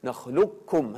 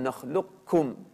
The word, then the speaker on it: نخلقكم: طبعا يعني هنا إما أن يكون الإدغام كاملا: ألم نخلقكم، نخلقكم هكذا بدون أن تنطق القاف أبدا، ألم نخلقكم من ماء مهين، أو يعني ممكن أن يعني يكون هناك أثر للقاف: ألم نخلقكم، ألم نخلقكم من ماء مهين، من ماء مهين، نطفة مهينة.